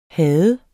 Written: have

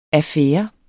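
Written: affære

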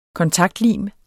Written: kontaktlim

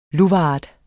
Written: luvart

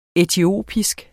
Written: etiopisk

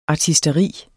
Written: ARTISTERI

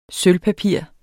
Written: sølvpapir

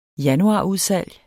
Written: januarudsalg

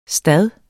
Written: stad

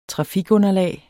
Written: trafikunderlag

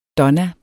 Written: donna

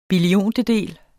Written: billiontedel

